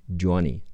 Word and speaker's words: Johnny: h 不发音，n e n 是两个 n 和 y 读 n e n，Johnny Johnny，美式发音是 j a j Johnny Johnny，